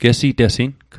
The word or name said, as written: gesitesing